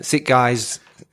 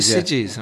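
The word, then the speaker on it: Sitges: They get a film, they get accepted into, I don't know, Sit Guys. Do you pronounce it Sidges? You pronounce it Sidges? Yeah. All right, okay. I've always pronounced that wrong.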